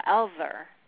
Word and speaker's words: Elfver: Well, actually, it's everything is correct, but how I learned to pronounce it is that an F is silent, so Elver.